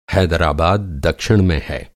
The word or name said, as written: दक्षिण